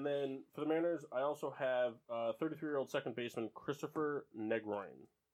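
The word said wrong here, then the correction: Negrín: I don't know if I'm saying that right. – Negrin. – Negroin? – Negrin.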